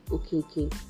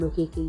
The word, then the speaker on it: Okeke: My name on Facebook is Mercy OKK. M-E-R-C-Y, that's Mercy, and OKK is spelled as O-K-E-K-E, Mercy OKK.